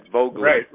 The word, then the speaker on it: Voegelin: Yes, and that's Eric Voegelin, who was a bit blurred out for my hearing, so it's V-O-E-G-E-L-I-N, something like that, Voegelin. – Right. So the German pronunciation is Voegelin.